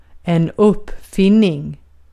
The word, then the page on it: invention: /ɪnˈvɛnʃən/